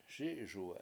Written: SI7iSuwe7

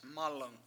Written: mAlung

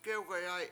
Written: gewgayAy

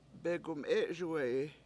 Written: begumE7Suwe7i

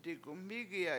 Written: digumMIIgi7a

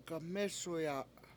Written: gamEsuya

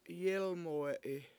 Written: yEElmuwe7i

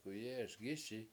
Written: k7uyE7eSgISi

